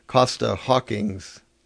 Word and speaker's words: Costa Hawkins: And that, I mean, that's interesting because you have Costa Hawkins. Did I say that right? – Yeah.